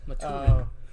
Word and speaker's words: Maturin: The turtle also appears in the Dark Tower series as Maturin. Am I saying that correctly? Maturin? – Maturin.